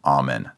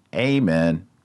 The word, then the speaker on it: Amen: Amen. Not amen. Remember, the long A in Latin is ah. Amen. Amen. That's how you say it.